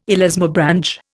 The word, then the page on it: elasmobranch: Indian